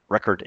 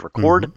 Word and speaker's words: record: In last week's show, I mentioned that in English, words that are both nouns and verbs have, when they're said aloud, stress on different syllables, so they sound different, like record and record.